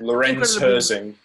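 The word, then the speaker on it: Lorenz Hörzing: He basically got a sim racer in, I think it's Lorenzo or Lorenzi Horzing, I probably pronounced that. – Lorenz Herzing, yeah. – I knew I'd pronounced that incredibly wrong, you know, but I suppose you need to make a rule sometimes.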